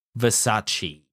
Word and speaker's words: Versace: Versace.